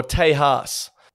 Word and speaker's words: Tejas: This week we are in Texas, or Tejas, as some people pronounce it.